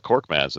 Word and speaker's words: Korkmaz: You got Korkmaz. You nailed Korkmaz in the opening. That was good. – We did. I had to slow down a little bit. That's a rough one for the pronunciation.